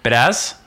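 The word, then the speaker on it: Biadasz: and then I don't know how to say this guy's last name, Tyler Bedaz. – That's pretty close.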